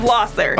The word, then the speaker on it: Lahser: off of Losser, okay. – not Lasher. – Lasher. – I'm telling all my business okay. on the internet. I live off of Losser. – Okay. – L-A-H-S-E-R.